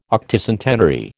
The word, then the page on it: Octocentenary: American English